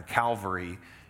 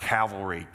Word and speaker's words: Calvary: If you ever heard that Jesus died on Calvary, it's just that hill of the skull. It's the same word, just in another language. It's not cavalry. Cavalry are the soldiers on horses. It's Calvary.